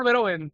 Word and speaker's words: Verhoeven: No, Paul Verhoeven. – Paul Verhoeven, vale. Bueno, ahí hay una H, entonces, que no se pronuncia.